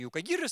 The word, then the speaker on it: юкагиры: Ну и с другой стороны планеты, юкагеры. Ну, иногда ударение юкагиры ставят, там и так и сяк говорят, но вроде как само название название юкайгеры, насколько я знаю.